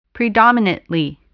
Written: predominately

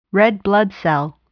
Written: red blood cell

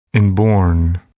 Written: inborn

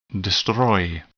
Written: destroy

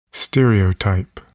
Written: stereotype

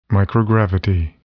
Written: microgravity

